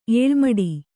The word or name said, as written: ಎಲುಮಡ